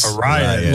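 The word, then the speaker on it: Arráez: How do you say his last name? Arias? – Arias.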